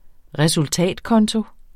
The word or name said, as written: resultatkonto